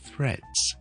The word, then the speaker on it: threads: 跟住完之后直接扔落去个 reds 度，听住 threads， and that's how you pronounce threads。